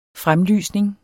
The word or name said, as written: fremlysning